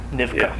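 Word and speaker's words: Nivkh: What main thing I got out of it was the correct pronunciation of Nivka, which is an Aboriginal culture in the Okhotsk region north of Hokkaido. It's spelled N-I-V-K-H. I never knew how to pronounce it. – Nivka.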